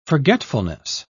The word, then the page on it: forgetfulness: British English